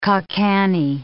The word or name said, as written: ca' canny